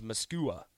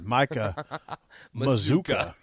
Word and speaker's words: Mazuka: So today, Micah Mazuka rhymes with bazooka. – Doesn't look like it if you look at how it's spelled, though. – No. – Because it looks like it should be pronounced Mascua.